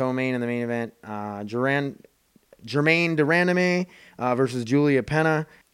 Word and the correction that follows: Julianna Peña: Julianna Peña is how you say it.